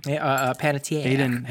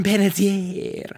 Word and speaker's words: Panettiere: How do you say her last name? – Panettiere. – Hayden Panera Bread. – Hayden Panettiere. I agree.